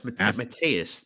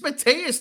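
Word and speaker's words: Matheus: This first name, Matthews Nicoloa. – What are you looking at? Oh, that's Mateus, bro. You don't know how to pronounce Mateus? – No, I'm fucking idiot from Boston.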